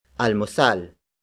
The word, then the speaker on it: almusal: And that is almusal. Almusal. Almusal. Almusal is spelled as A-L-M-U-S-A-L.